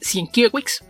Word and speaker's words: Sienkiewicz: que su apellido se escribe Sienkiewicz, pero evidentemente como es polaco pues la pronunciación no es la misma, y él incluso en sus redes sociales lo escribe de una forma que es más fácil entender, que es el Kiev, la parte que se acentúa, y entonces se debe leer Sienkiewicz, es la pronunciación correcta de su apellido.